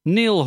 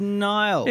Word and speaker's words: Niall: Brackets, nobody's pronouncing my name correctly. – I think a lot of people pronounce it correctly. – It's Neil. – It's Niall. – It's Neil. – BBC, say Niall. – Well, the BBC are wrong. – Well, they're wrong and I'm correct.